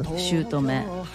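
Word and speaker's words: 姑: シュート目。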